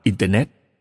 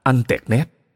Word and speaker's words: Internet: Bởi vì ông Uve không tin vào mạng Internet, ông cứ thích phát âm nó thành anh tẹt nét. Mặc dù vợ ông khăng khăng cho rằng ông phải phát âm là internet mới đúng